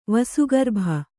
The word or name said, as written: ವಸುಗರ್ಭ